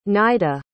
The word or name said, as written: Nida